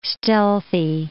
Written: stealthy

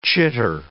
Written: chitter

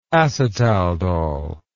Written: acetaldol

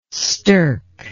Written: stirk

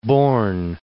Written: Borgne, Lake

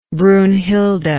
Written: brunnhilde

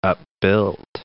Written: upbuilt

